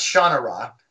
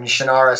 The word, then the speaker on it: Shannara: Finding out that I had been mispronouncing Shannara forever. – Ever. – It's Shannara. But I still can't say it that way because it's been Shannara since I was, you know, 12.